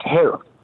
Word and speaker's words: harem: Yeah, well, that's where it originates from, but it's pronounced harem.